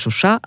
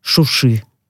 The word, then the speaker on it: Шуша: Тут вот очень важно, да, правильно ставить ударение, потому что оно имеет такой политический оттенок, поскольку азербайджанцы называют этот город Шуша, а армяне Шуши.